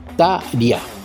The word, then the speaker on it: Tarja: Tarja Turunen, aunque aquí dice que se pronuncia Tarja.